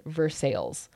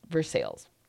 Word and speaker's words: Versailles: They are going back to Kentucky and getting married at a castle in Versailles, which is supposed to... It's in Versailles? Like okay. – It's spelt like Versailles, but in Kentucky, they call the place Versailles.